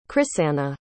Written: chrisanna

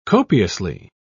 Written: copiously

